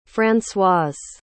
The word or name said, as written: francoise